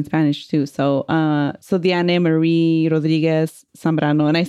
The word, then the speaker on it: Diane: So um, I'm going to be talking about Diane, and I think I read an article that she pronounces it Diane, because it's actually oh. – like to say it in like split in three syllables.